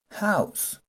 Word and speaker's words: house: H O U S E así Jouse, o use algo así sería en español pero va otra vez H O U S E y se pronuncia house house recuerden my people eh, la H en inglés suena como una J entonces, la mayoría de palabras que empiezan con H van a sonar como si sonara una J, como si fuera una J.